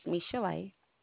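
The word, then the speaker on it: Michel'le: My name is Michelle. – Okay, could you spell that, please? – Sure. M-I-C-H-E-L apostrophe L-E.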